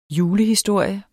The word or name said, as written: julehistorie